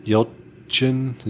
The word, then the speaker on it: Yelchin: Well, yeah, because that also had Anton Yelchin.